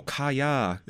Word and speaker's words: Kaya: Yeah, it's four letters, but because it's just not a Western name, so everyone's like, Kaya? Kai? It's like, no, Kaya. It's two syllables. Why is this so fucking difficult?